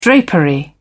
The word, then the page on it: drapery: British English